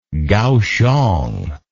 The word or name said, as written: Gaoxiong